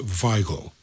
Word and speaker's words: Weigl: that's Weigl. That's spelled W-E-I-G-L.